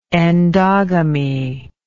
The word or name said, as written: endogamic